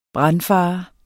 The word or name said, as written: brandfare